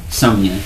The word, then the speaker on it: Sommelier: Sommelier. – Sommelier? Like a wine sommelier? – No, but we can make it sound...